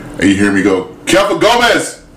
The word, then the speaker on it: Kepha: Kefa is K-E-P-H-A. It's Aramaic for Peter.